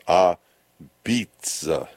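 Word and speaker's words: apizza: They're calling it, they're writing it out, a pizza, but they say it's pronounced a beets.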